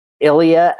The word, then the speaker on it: Ilija: First one that picked up the phone was a gentleman named Ilija Mušković, and his name is spelled I-L-I-J-A, which ironically looks a little bit like Elijah, but it is Ilija.